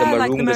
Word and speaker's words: moringa: If I'm going to pronounce correctly, because I don't really know how to pronounce correctly, it's called marunga leaves. So we're making like a moringa syrup. – Yeah, the moringa.